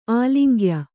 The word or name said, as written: ಆಲಿಂಗ್ಯ